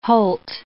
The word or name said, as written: holt